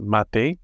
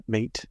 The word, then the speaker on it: mate: pronounced mate. Not mate.